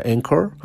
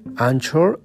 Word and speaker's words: Anchor: Este, y es eh, en inglés, creo que se pronuncia Anchor. Anchor, así se escribe A-N-C-H-O-R.